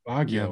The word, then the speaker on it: Baguio: Is how you say it? Uh, Baguio. Baguio. Baguio.